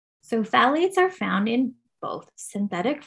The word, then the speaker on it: phthalates: Perfumes, because they synthetic fragrances inherently contain a class of compounds called phthalates, which is also starts with a P. It's a silent P, I guess. And it's phthalates.